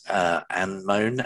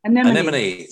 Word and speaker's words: anemone: I may pronounce this wrongly, but it's Anemone and Catharsis, and she's got it on a anemone. – Sorry, anemone. I'm terrible.